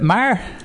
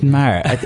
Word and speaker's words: Meagher: Is it Meagher? – Yeah, that'd be the right way to pronounce it. – Meagher.